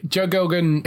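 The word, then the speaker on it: Joe Gilgun: But she's in that, and you've also got, is it Joe Gilgan? I think that's how you say it. – Yeah, Joe Gilgan, who is, it's actually his show.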